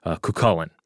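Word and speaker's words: Cú Chulainn: And so I asked him, hey, how would you say this? And he told me that it would be Cuchulainn.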